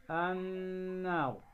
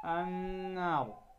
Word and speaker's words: النار: فإذا نطقنا كلمة النار في كتاب الله، هذه النون نون مشددة، فلا بد إذن أن نظهر الغنة بمقدار حركتين لا أكثر، فنقول النار، فنقول النار.